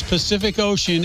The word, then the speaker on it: Pacific Ocean: Every letter C in the words Pacific Ocean is pronounced differently. First one is like an S. Second one like a K, and the last one like an SH.